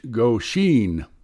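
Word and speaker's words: Goshin: like the famous living artifact known as Goshen, pronounced Goshen.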